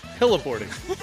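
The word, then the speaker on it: heli-boarding: Hellaboarding. Yeah. Not like hella, H-E-L-L-A, hyphen boarding, but hella as in helicopter, snowboarding.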